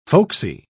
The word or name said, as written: folksy